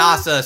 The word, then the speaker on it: Nasa: NASA, spelled like NASA.